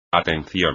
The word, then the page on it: atención: espagnol